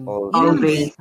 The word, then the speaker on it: Alvvays: Always spelled as A-L-V-V-A-Y-S. So, yun. – Always. – Parang siyang Always pero Always yung pag-pronounce.